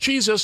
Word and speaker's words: Cheeza: The name is for a mashup of chicken and pizza, and it's pronounced Cheeza, so it rhymes with pizza.